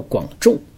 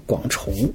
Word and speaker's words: 广重: 呃，首先来说说它名字的读音啊，很多人都把广重呢读作广重，啊，我其实原来也是这么读的，歌川广重，但是在东京看展览的时候啊，中文语音导览把他的名字读作广重啊，就是重庆的重，我想也许人家是经过精心考证的吧，啊，认为这个读法更可靠吧，所以从那以后我也都把它念成重，呃，因为要做这期节目呢，我想再严谨一点，我就搜索了一下。